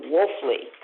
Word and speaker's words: Wolfley: Not true for my last name, Wolfley. If people hear it first, they spell it all sorts of bizarre ways and never the right way. If they see it written first, they never pronounce it the way I do.